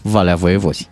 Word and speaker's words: Valea Voievozilor: Dar uh, și localitatea absolut normală la cap în denumirea ei Valea Voievozilor, dar mm. pe care localnicii o pronunță foarte frumos, Valea Voievozi, Așa.